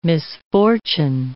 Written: misfortune

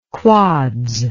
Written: quads

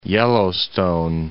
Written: Yellowstone

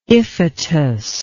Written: Iphitus

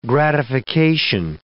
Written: gratification

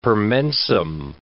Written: per mensem

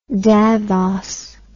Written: davos

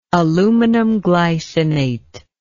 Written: aluminum glycinate

